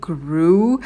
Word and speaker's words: grew: Grow, grew. В слове grew делайте звуковую волну, у нас долгий U.